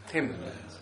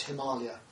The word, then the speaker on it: Himalaya: And so really it should be pronounced Himalaya, by the way, not Himalaya. So Ālaya is the word for a store, a storehouse.